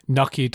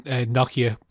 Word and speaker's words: Nokia: And also, I've always grown up calling it Nokia, but apparently it's Nokia. Apparently the actual proper pronunciation is Nokia, but everyone, especially in Ireland, called it Nokia.